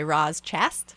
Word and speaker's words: Roz Chast: Going Into Town, A Love Letter to New York by Roz Chast. That's how you pronounce that? – Yes.